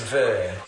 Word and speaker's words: WIR: The Vir Bank, that's pronounced Vir, W-I-R, Bank,